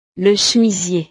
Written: le chemisier

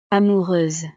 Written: amoureuse

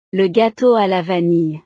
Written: le gateau a la vanille

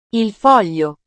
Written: il foglio

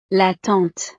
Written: la tante

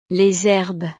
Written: les herbes

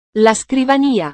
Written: la scrivania

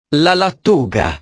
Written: la lattuga

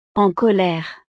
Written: en colere

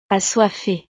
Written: assoiffee

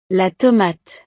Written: la tomate

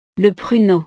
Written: le pruneau

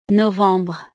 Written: novembre

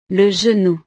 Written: le genou

